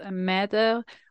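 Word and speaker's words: Mätte: Like, I know because my last name is Mette, which is actually spelled with the two dots above the A, the German spelling.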